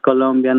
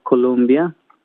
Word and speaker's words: Colombia: Bueno, todo comenzó en el 2020, en febrero, para ser más exactos, cuando yo quería una camiseta que dijera It's Colombia, not Columbia, pero porque es una palabra que muchas personas acá en Australia pronuncian mal. El nombre de nuestro país lo pronuncian Colombia,